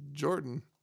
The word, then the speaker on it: Jordan: It's pronounced Jordan. Oh, okay. It's like Jordan. Jordan. Not French.